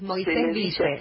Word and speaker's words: Moisésville: yo le dije Moisésville. Es Moisésville, así como se escribe.